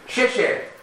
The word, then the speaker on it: xie xie: Xie xie. Did I say that right? – Yes.